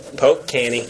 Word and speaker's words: Polk County: Or O-U-N-T. – No. Just Canny. Poke Canny. Yeah. – For all you regular people out there, that's Polk County. – Yes. Yeah, that's... – Poke Canny is redneck for Polk County.